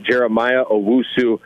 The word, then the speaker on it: Jeremiah Owusu-Koramoah: But if you look at a guy out of Notre Dame, Jeremiah Owusu-Koramoah is how you say it.